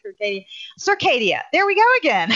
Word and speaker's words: Circadia: Am I saying that right? – Circadia. – Yeah. Okay. I always say Circadia.